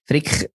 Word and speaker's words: Frick: Frick, ist deutsch ausgesprochen, nicht Englisch.